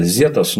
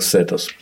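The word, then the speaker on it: Zetas: Образовался картель, если правильно помню, там Лос Сетос, ну, он пишется Зетос, но читается вроде как Сетос.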